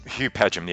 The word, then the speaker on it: Padgham: Is it Hugh Padgham? – Hugh Padgham, yeah, that's right, yeah.